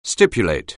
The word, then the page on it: stipulate: anglais